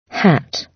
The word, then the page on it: hat: British English